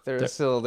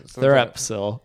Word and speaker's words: Therapsil: Therasil. There's a silent P in there. – Therapsil.